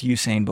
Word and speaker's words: Usain: John, we had a caller actually call in and they commended you on the pronunciation. It is Usain Bolt.